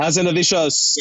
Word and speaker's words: Hazanavicius: Hazanavicius.